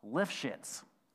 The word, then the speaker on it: Lifshitz: Ralph Lifshitz. And I did not mispronounce that.